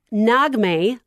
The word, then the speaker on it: Nagme: It's Nagme, like eggnog and May, so you guys get it. That's the way she's described it to me.